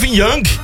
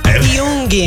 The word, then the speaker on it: Jung: Rapaz, eu aprendi quem é Carl Gustav Jung. – Jung. – Corrigiu a pronúncia do psicólogo, parabéns.